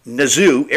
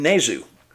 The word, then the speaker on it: Nazoo: Um, you know, I've been saying his name wrong. It's Nazoo. It rhymes with kazoo.